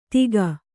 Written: ತೀಗ

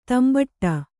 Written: ದಂಬಡಿ

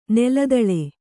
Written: ನೆಲದಾಳೆ

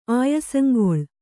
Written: ಆಯಸಂಗೊಳ್

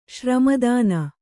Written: ಶ್ರಮದಾನ

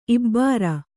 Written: ಇಬ್ಬರ್

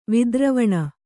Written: ವಿದ್ರವಣ

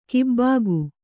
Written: ಗಬ್ಬಿಗೆ

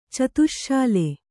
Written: ಚತುಶ್ಶಾಲೆ

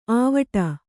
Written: ಆವಟ

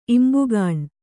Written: ಇಂಬುಗಾಣ್

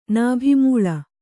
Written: ನಾಭಿಮೂಳ

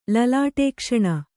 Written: ಲಲಾಟೇಕ್ಷಣ

